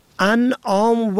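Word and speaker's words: اَنعام: ان آم و ان آم، این دو واژه در تلفظ و معنا متفاوت است. انعام با فتح اول جمع نعم به معنای چارپایان است، اما انعام با کسر اول به مفهوم بخشش است.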